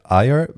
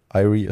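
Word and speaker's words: Eyre: Ähm, der Regie, äh, Regisseur war Richard Eyer, würde ich es aussprechen. Irie ist, glaube ich, falsch.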